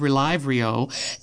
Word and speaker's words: Relyvrio: This is spelled R-E-L-Y-V-R-I-O, and I'm going to call it Relyvrio.